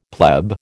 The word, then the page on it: pleb: American English